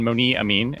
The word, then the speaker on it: Monee Amin: So I'm pleased to introduce Meredith Trubit and Moni Amin.